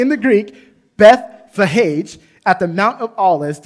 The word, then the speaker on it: Bethphage: I actually looked up how to say that. Bethphage, that's how you say it in the Greek.